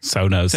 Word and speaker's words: Sonos: En natuurlijk Sonos. – Sonos.